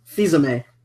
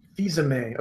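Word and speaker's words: Fils-Aimé: Fizame. – Fizame.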